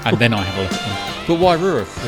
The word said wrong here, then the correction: Waiouru: was uh, really interesting, actually. – Waiouru? – Sorry, yes, not very good at pronunciations yet.